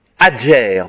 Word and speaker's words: Ager: Ager, adger plutôt, hein, excusez-moi, là je prononce en détruite.